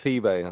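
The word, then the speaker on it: pibe: Is that how you say it? Peebe or Peeb? The kid. – Peebe. – Yeah, the kid. – Yeah. They probably say one in Spain and one in Latin America.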